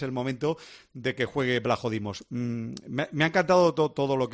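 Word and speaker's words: Vlachodimos: Blajodimos, sí, sí, así se pronuncia, Blajodimos.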